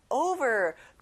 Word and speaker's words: over: 덮이도록 위에 over, 친구들 over가 아니라 o 발음이 나요. Over.